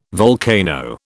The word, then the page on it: volcano: British English